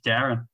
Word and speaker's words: Darran: I said, it's Darren. And then they went up and introduced me as Daryl.